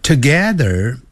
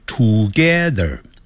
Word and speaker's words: together: I live together 不要念成 together，要念 together together。那个 T-O-G-E-T-H-E-R 有个 R 结尾，所以在美式的发音呢要卷舌的。